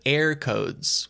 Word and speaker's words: Eircode: Yeah, it's a system called Air Codes, which is spelled EIRCODE. Like the air as in the country rather than air as in the part of that isn't the ground. – Yeah, I checked the pronunciation in like an Irish news video, and I kept hearing it as like AirPods, but that's just my dumb American hearing of it. – No, I think the pronunciation is pretty much the same.